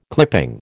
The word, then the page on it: clipping: American English